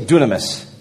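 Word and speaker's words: dunamis (δύναμις): We get our word dynamite from it, or dynamo, and it's pronounced dunamis. So turn to your neighbor and say dunamis.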